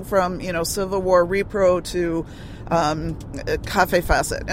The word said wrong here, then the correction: Kaffe Fassett: I'm sorry, I did that again. Kaffe Fassett. Boy, I haven't mispronounced his name in a long time.